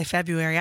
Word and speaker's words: February: I say February. I skip that R.